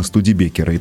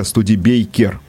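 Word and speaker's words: Studebaker: после где-то 42-43 года как шасси использовали именно студибекеры. И тогда же немножко неправильное произношение этой, э, этого названия у нас укоренилось именно студибекер, хотя студибейкер было бы правильно говорить, но вот, вот угу.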